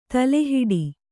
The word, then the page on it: ತಲೆಹಿಡಿ: tale hiḍi